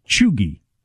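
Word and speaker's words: cheugy: You pronounce it Chugi. Chugi. – Yes. – Chugi? – Chugi. – Chugi. – A soft G? – I can't tell. It just has G-E-E, so would that be a hard or a soft? – Hard to say.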